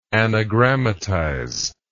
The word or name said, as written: anagrammatist